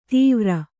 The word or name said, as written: ತೀವ್ರ